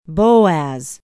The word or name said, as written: boaz